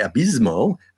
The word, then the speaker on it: Abismo: And on the same show, Al Zorro defeated Abissimo. Uh, sorry, Abismo, uh, Negro Jr., Mr. Nebula, and Alan Stone in an elimination match to win the 2008 Rey de Reyes tournament.